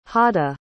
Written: hada

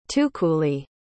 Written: tukuli

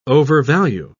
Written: overvalue

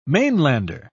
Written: mainlander

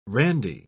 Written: Randy

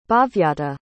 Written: bhavyata

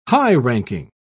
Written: high-ranking

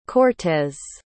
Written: cortéz

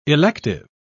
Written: elective

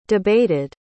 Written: debated

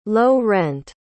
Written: low-rent

